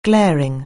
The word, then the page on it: glaring: anglais